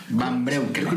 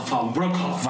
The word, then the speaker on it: Van Breukelen: Van Breukelen. – Creo que se pronunciaba Van Breukelen. – Van Breukelen. Sí, sí.